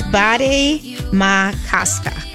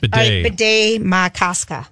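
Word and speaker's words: Bde Maka Ska: It's Bade Makaska. Kaska, right? – I thought it was Bade. – It is Bade. – Bade Makaska. All right, okay.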